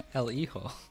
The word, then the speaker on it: El Hijo: El Hijo. – Sure it is. There's an H in there. What are you talking about? – The H is silent. The J is an H.